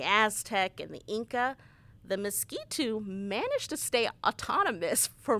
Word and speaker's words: Miskito: Well, you mentioned Nicaragua, and that's another thing I wanted to talk about, the relationship with the groups of indigenous people, the Miskito. Am I saying that right? – Correct.